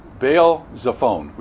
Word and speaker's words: Baal Zephon: Baal Zephon, we pronounce it.